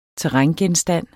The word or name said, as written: terrængenstand